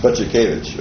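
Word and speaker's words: Budzikiewicz: Z-I-K-I-E-W-I-C-Z. Yes, Butzikiewicz.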